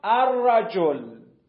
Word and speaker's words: الرجل: ولی در ادغام متقاربین دقت کن اینجا بومد، اون حرفه تبدیل به حرف دومی نمیشه، اصلا حرفی به حرف تبدیل نمیشه، توی تلفظ تبدیل میشه الرجل الرجل. لام و را، لام و به شیوه را داریم میخونیم الرجل.